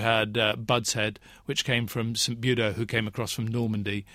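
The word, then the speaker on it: St. Budeaux: And you also get St. Budo, only originally it was B-U-D-O, and we French... Frenchified it to become B-U-D-E-A-U-X. – Very good. – But it's the same pronunciation, of course.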